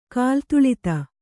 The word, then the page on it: ಕಾಲ್ತುಳಿತ: kāltuḷita